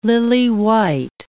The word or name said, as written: lily-white